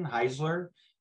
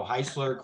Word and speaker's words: Heisler: And a lot of people go with the Z sound for some reason, Heisler, but it's originally an Austrian name. And the simplest way I've found to explain it is it rhymes with Chrysler. So Heisler, Chrysler.